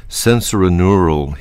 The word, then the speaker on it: sensorineural: So you suffer from sensorineural hearing loss, if I pronounced it correctly. Could you explain what that is? – Sure. You did pronounce it correctly.